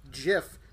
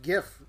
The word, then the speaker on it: GIF: Like, I'm arguing with the creator of GIF, that it's not pronounced GIF, it's pronounced GIF.